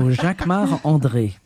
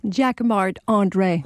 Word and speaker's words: Jacquemart-André: Jacquemart-André. Jacques or no, you say it like André. A Frenchman. – Jacques of course, André. Because I'm French. So Jacquemart-André.